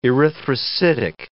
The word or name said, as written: erythrocytic